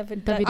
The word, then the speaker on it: Davido: Davido.